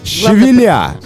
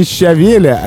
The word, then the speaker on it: щавеля: Щавеля. Не щавеля, не щавеля, а щавеля. – Щавеля. – Да, щавеля. Оказывается, это правильное ударение.